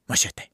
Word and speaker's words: Machete: And you have to say it like that, Machete. All right, because Machete Kills doesn't sound as cool.